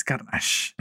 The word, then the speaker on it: Carnage: Es Carnage.